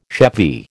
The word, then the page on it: chevy: American English